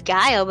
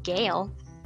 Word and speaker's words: Gayle: I thought it was Guile, but apparently it's Gale. – How do you spell it? – G-A-Y-L-E.